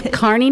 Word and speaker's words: Kearney: So we're next up is Kearney, Nebraska. I always say that wrong. I'm sorry. Kearney, Nebraska.